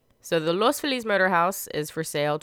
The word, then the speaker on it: Los Feliz: It's Los Feliz, the happy, not Los Feliz.